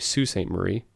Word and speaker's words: Sault Ste. Marie: Ted Nolan is from outside of Ian's hometown, which we mispronounced last week. It's actually Sault Ste. Marie.